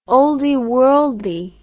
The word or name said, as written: olde worlde